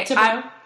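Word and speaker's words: Thibodaux: One was from Teresa Huckins of Thibodeau. Oh, I totally said that wrong. What is it? How do you say it? Okay. – Thibodeau. – Okay. Thibodeau.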